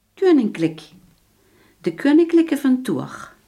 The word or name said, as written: koninklijk